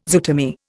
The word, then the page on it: zootomy: Indian